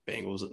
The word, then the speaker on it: Bengals: Bengals, really? The Tiger, Bengals, orange stripes, Bengals. Yes. Okay. Now that I have the image of a bagel out of my head, now I can say it correctly.